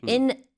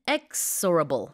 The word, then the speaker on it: inexorable: Let's try again then. Inexorable. – Inexorable, is that right? – Yeah.